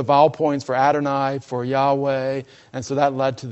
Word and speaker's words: Yahweh: But most scholars today think that it was pronounced Yahweh.